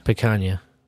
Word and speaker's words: picanha: Picanha.